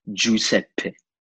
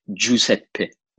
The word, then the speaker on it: Giuseppe: Yeah, the actual way to pronounce my name is Giuseppe, with a double P at the end, like Giuseppe, you know? Mm-hmm. Like this is like the purest form of, you know, pronouncing in the most Italian way, Giuseppe, you know? – With the hand motion and all.